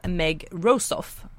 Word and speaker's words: Meg Rosoff: det var Någonting vad som helst av Meg Rosoff, uttalas det så? – Ja, just det.